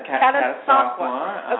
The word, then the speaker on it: Catasauqua: Catasauqua.